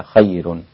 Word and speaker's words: خير: خير ميبقاش ياء تحت وبعد كده أطلع فوق أجيب الراء المفخمة، لا خليها خير وهكذا.